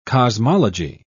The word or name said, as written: cosmology